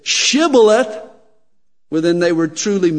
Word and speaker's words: shibboleth: But the dialect spoken by the men of Ephraim made it difficult for them to pronounce that sh sound. And so it would always come out sibboleth.